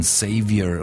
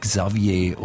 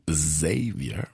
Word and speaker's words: Xavier: Xavier, damit wir das auch noch geklärt haben, woher kommt dieser Name und vor allem, wie Sie ihn aussprechen, Xavier und eben nicht Xavier oder Xaver? – Meine Mutter sagt eben dieses stimmhafte S, Xavier, weil da, wo sie herkommt, also in Südafrika, wird es halt so gesprochen, oder die Engländer sprechen so, die Amerikaner sagen wiederum Xavier.